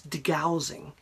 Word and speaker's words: degaussing: whereas if they hadn't done this degaussing, which rhymes with delousing deliberately, then, you know, how many dozens of ships would have been sunk?